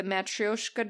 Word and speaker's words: Matryoshka: Matryoshka.